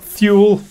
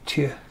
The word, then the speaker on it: Thule: But to give a bit more of a, because you mentioned it, now you pronounced it Tula Society. – Tula, yeah, Tula. – Tula Society, I always thought Thule, but then it's a word I've only ever read. – Germans don't really say th, it's always tula.